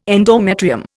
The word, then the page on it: endometrium: Indian